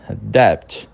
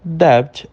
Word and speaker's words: debt: Segundo, aí da nossa lista, nós temos debt. Debt é a mesma situação. O B, ele é pronunciado, só que ele é tão levinho, que a gente considera ele silenciado.